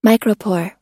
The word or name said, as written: micropore